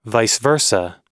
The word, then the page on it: vice versa: American English